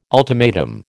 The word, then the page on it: ultimatum: American English